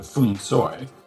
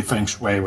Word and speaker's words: Feng Shui: So if you listen to a Cantonese movie and hear them say those words, they say something closer to Fung Soi, but no one says that. And so you can't be understood while saying it. So I used to just do it phonetically and say Feng Shui, but now I say Feng Shui, which is what people say in North America.